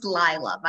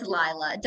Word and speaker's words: Leela: It's Leela. Don't worry. Almost everyone, it seems, pronounces my name Leela, or they butcher it in some way. I get like any other L name but my actual name.